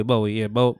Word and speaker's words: Bowie: Yeah, it's like David Bowie. Bowie. – Whatever your GPS says, that's what it is. – That is facts. Mm-hmm. we went to Bowie real quick because I was just riding.